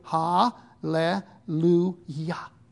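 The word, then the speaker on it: Ha, Le, Lu, Ya (הללויה): So we have Ha, Le, Lu, Ya.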